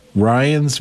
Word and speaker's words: Ryannes: Ryan's Vintage, let me spell that, R-Y-A-N-N-E-S, Vintage, and that's all one word.